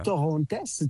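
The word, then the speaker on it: Torrontés: Torontos is the name of the grape. – Yeah. – Torontes, T-O-R.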